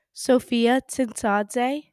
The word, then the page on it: Sophia Tsintsadze: female